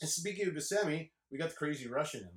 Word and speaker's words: Buscemi: Even like Steve Buscemi, never knew how to say his last name. Me and my brother always said Bushkamushki. – Oh, yeah, that's right. – Steve Bushkamushki. – That's right. I always said Buscemi because what was it?